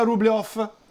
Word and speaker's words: Rublev: Gli risponde Martina, per fortuna, che dice è omonimo di un famoso pittore russo, si pronuncia Rublev.